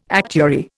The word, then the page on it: actuary: Indian